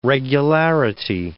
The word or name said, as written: regularity